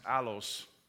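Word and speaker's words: allos (ἄλλος): That word another is an interesting Greek word because that word actually means is pronounced allos in Greek.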